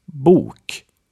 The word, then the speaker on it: bok: First, we have a long U, like in bok, which means book. Bok.